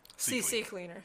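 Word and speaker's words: CCleaner: Do you pronounce it C-Cleaner or CC Cleaner? – C-Cleaner. – C-Cleaner. – I always say CC Cleaner.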